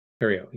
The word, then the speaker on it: Cariou: Is your last name pronounced Cariou? – Cariou, yeah.